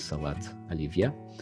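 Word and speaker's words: salat olivia (салат оливье): And in Russian, it's pronounced in a similar way, which is salat olivia.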